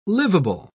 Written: livable

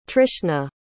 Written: trishna

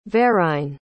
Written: verine

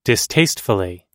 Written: distastefully

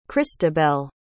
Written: christabel

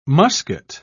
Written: muscat

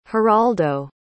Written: heraldo

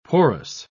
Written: porous

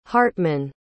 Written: hardtman

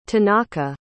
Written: tanaka

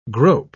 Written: grope

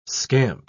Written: scamp

